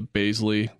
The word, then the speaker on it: Bazley: I don't think I have a Bazley. There, I said it right. Bazley piece in me today.